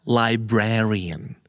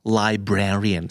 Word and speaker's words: librarian: อ่านว่า librarian librarian stress ตัวที่สอง